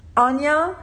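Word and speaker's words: Áine: Euh Anya, ce qui s'écrit A-I-N-E, comme ça ne se prononce pas parce qu'elle est irlandaise aussi.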